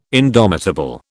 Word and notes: indomitable: British English

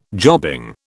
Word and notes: jobbing: British English

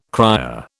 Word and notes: cryer: British English

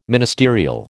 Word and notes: ministerial: American English

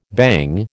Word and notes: bhang: American English

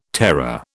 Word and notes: terror: British English

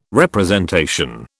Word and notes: representation: British English

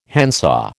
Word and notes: handsaw: American English

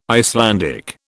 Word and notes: Icelandic: British English

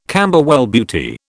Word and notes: Camberwell Beauty: British English

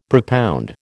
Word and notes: propound: American English

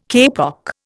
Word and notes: kapok: Indian

